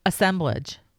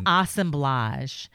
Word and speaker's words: assemblage: Assemblage. – No. The way the art snobs say. – Oh, assemblage. – Assemblage. – Yeah, think of collage.